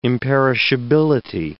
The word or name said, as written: imperishability